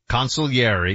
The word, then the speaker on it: consigliere: Consigliere, because we don't pronounce the G in Italian.